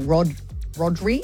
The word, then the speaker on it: Rodri: Firstly, because Barcelona has just signed Spanish midfielder Maestro Rodri.